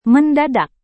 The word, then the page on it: mendadak: Indonesian